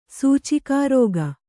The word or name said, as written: ಸೂಚಿಕಾರೋಗ